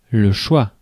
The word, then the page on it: choix: [ʃwa]